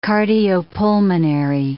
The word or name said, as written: cardiopulmonary